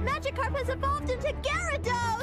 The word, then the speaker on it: Gyarados: Its name isn't Gyarados. It's Gyarados. – Yeah, but I don't think you pronounce that A. – It's Gyarados. G-Y-A-R-A-D-O-S.